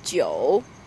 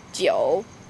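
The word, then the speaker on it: Jiu (九): Nai is pronounced Jiu, and that's exactly the same as long time or eternity, Jiu.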